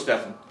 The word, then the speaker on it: Stefan: Yeah, he goes Stefan.